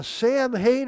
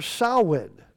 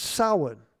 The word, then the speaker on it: Samhain: Well, that's not the proper pronunciation. Irish Gaelic is, it means for summer's end, and the pronunciation is so in with the O-W like a cow, or Samhain. It's not Samhain. It's Samhain.